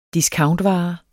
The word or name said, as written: discountvare